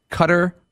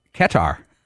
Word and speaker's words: Qatar: So I've got at least five different pronunciations that are acceptable. Qatar, Qatar, Qatar, but the... Oh, man. Qatar Airlines pronounces it Qatar, like almost like a cat.